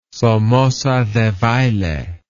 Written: somoza debayle

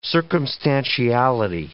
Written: circumstantiality

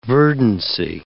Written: verdancy

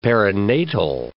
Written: perinatal

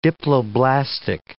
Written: diploblastic

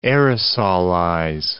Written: aerosolize

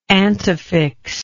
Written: antefixal

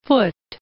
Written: Foote

